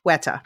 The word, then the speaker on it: Weta: Weta. I'm pronouncing it too Dutch. Weta.